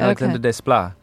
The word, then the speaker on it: Alexandre Desplat: Am I saying his name right, by the way? – Yes, that's about right. Okay. Alexandre Desplat.